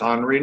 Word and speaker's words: Henri: For those of you who are classically trained and familiar with French, we would refer to him as Henri Nouwen. On the other hand, Michael reminds me that we don't pronounce Gravois Gravois or Carondelet Carondelet. So in deference to our rector, we'll talk tonight about the wisdom of Henry Nouwen and Vida Dutton Scudder.